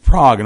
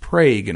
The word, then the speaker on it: Prague: We have a town in Oklahoma called Prague. It's not called Prague. It looks like Prague, as in the European city, but it's not pronounced Prague in Oklahoma.